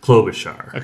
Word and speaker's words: Klobuchar: Because you said Klobuchar. – Yeah. – But it's Klobuchar. – Okay. – Yeah. With the first syllable rhyming with the word blow.